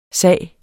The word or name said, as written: sag